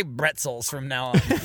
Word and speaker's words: bretzel: Because I fucking love that pretzels is just bretzels. – Bretzels. – Yeah, it's spelled exactly it's the same way, except yeah, it's French. A B. They say bretzel in French.